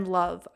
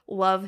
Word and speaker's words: Lauv: And then Love. I think that's how you say it. L-A-U-V. Love.